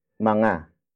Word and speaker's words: mga: Up next is mga. Mga. Mga. Mga is spelled as M-G-A.